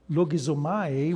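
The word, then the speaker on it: logizomai (λογίζομαι): The word reckon is the word logizomai, better pronounced.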